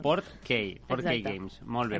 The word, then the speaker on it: Portkey: He pronunciat malament, és Portkey. – Portkey. – Sí. – Ah, no de no porquet.